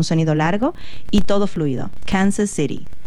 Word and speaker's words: Kansas City: No, usted, o sea, en español pronuncia City. – Y es Kansas City. City, o sea, la T suena como R. City.